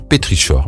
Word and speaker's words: pétrichor: Avouez que vous n'avez sûrement jamais entendu ni utilisé ce mot dont le son K s'écrit CH. On pourrait être tenté de dire pétrichor, mais non, c'est pétrichor. Bien